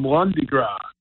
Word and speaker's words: Lundi Gras: We call them Lundi Gras because that's how you say it in French on Mardi Gras.